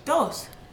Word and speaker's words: Dhos: We have, oh God, how do you pronounce this? It's spelled D-H-O-S. – Dos.